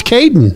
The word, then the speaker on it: Caiyeiaden: C-A-I-Y. E-I-A-D-E-N. – Lord. How in the world do you say that? – And it's just pronounced Caden.